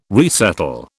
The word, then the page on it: resettle: British English